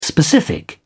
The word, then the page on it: specific: British English